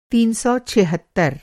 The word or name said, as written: तीन सौ छिहत्तर